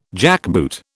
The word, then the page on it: jackboot: British English